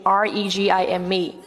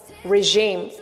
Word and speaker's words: regime: We can pronounce it like regime or regime. Okay, regime. The two accents are different from the different accents in British accent and American accent. Okay, in American accent, we more often pronounce it like regime. E pronounced like E.